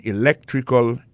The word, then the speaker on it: electrical: I majored in electrical engineering. Electrical engineering. Ça veut dire, accent tombé dans electrical, là, tombé sur deuxième syllabe là, electrical engineering. C'est sur deuxième syllabe là, elect là, plus appuyé.